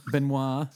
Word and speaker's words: Benoît: So Benoit Poulard, or however you say his name. – Benoit. – Sorry, that's right. It's French.